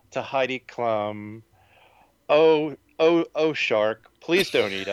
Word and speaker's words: Klum: And I'm so glad you pronounce it Klum, because Klum kind of rhymes with chum.